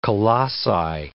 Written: colossi

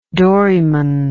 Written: doryman

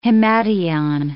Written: himation

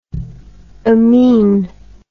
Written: amin

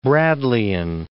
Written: Bradleyan

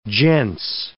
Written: gents

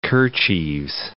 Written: kerchieves

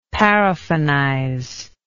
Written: paraffinize

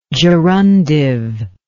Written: gerundively

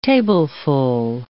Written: tableful

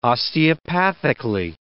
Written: osteopathically

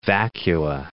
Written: vacua

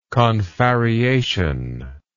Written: confarreation

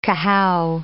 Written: cahow

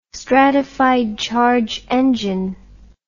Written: stratified charge engine

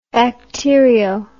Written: bacterio

